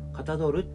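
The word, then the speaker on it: 象る: かたどる」って読むんですよね。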